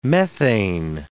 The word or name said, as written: methane